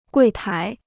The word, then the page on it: 柜台: guì tái